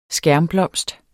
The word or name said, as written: skærmblomst